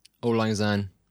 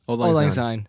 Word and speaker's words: Auld Lang Syne: Auld Lang Syne. – Okay. Auld Lang Syne.